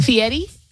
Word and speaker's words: Fieri: What was his last name, sorry? – Fieri? – Yeah, it's just how you say it.